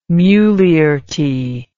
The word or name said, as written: mulierty